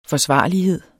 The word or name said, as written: forsvarlighed